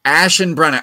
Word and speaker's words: Aschenbrenner: Aschenbrenner. I would have not got that. I would have I would have not even close. Aschenbrenner.